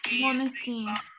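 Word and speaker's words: Måneskin: Es, pero mira, escucha cómo ellos lo pronuncian. Escucha. – Thank you for listening to us and getting us on your rep this year. Moneskin. Moneskin. Bueno, vamos a decirle Moneskin. Con Conste que ellos dijeron que así se pronunciaba.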